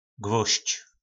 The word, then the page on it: gvozdz: po nasimu